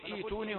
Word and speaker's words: ائتوني: السابق الحركة هنا همزة مكسورة يبدأ بها مكسورة، وهناك أيضا يبدأ بها مكسورة، فنقول إيتوني هنا وإيتوني هناك.